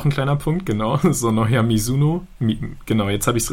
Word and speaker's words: Mizuno: Das hat nämlich auch der User Biolek unter einer der News geschrieben, dass sie nicht Mizuno ausgesprochen wird, sondern Mizuno, also mit einem weichen S.